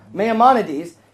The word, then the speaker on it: Maimonides: There's a guy named, if I can say his name right, Maimonides. I did that pretty well. Maimonides.